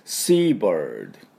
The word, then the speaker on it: seabird: ではですね、海鳥、まあ、全て、まあ、あのカモメとかいろんなの言いますけども、ペリカンとかそういうの全て含めて海鳥、これはですね、何て言うかというと Seabird、 Seabird なんです。 S-E-A-B-I-R-D ですね。まあ、そのままですね、海鳥、ね、海と鳥がくっついてます。で、これ発音簡単なんですけども、意外とできてない人多いんでやっていきましょう。まず SEA はですね、 C、これ空気の抜ける音ですね。